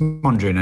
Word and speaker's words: Mondrian: It, am I saying it right? Montreal, Henri Montreal, or Pierre Montreal. Piet Montreal. It's Mondrian anyway.